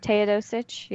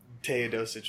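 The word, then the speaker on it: Teodosić: I think it's pronounced Teodosic, but Teodosic, yeah.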